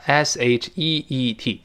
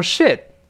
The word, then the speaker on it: sheet: 那我在新东方在线讲的这门课啊，s h e e t 跟老师读一下 sheet，sheet，哎，这是床单 s h e e t，可不要读成 shit。